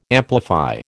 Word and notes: amplify: American English

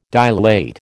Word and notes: dilate: American English

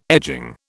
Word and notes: edging: British English

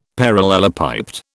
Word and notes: parallelepiped: British English

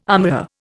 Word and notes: armorer: Indian

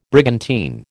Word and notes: brigantine: American English

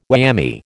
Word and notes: whammy: American English

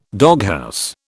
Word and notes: doghouse: British English

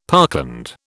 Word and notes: parkland: British English

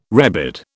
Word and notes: rebid: British English

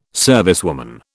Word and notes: servicewoman: British English

